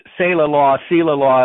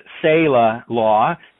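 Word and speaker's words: Seila Law: I think I pronounced Sailor Law at one point, but it's Sailor Law.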